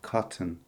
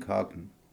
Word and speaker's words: cotton: I'm not sure, but I think it's because they pronounce the T of cotton clearly. American English speakers don't pronounce the T in cotton, but they pronounce it in hot dog.